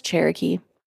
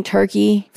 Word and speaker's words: Cherokee: My name is Cherokee. It's a hard name to pronounce. I get it. Most kids call me Turkey for the first few years of their life.